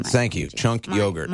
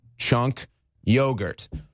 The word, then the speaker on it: Cenk Uygur: Cenk Uygur. – My apologies. – It's not that hard. You saw Hassan Piker frustrated with CNN. Hassan, we're saying it right. Cenk Uygur.